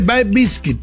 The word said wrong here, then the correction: Bay Biscuit: Se dice Bay Biscuit. – Bay Biscuit. – Porque es como bizcocho de la bahía.